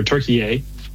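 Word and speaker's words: Türkiye: Turkey or Turkey-A. Mm-hmm. As the proper pronunciation.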